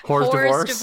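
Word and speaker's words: hors d'oeuvre: So Jenny, for him, is more of an hors divorce. Did I say that right? – Hors divorce. Hors divorce. – There we go.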